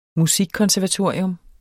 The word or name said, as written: musikkonservatorium